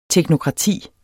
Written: TEKNOKRATI

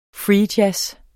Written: jazz

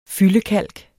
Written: fyldekalk

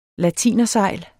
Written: latinersejl

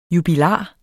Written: JUBILAREN

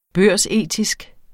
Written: børsetisk

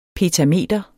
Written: petameter

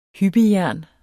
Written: hyppejern

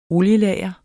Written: olielager

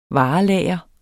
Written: varelager

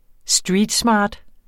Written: streetsmart

